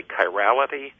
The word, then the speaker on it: chirality: There's something called, and I hope I'm pronouncing it correctly, chirality. – You got it.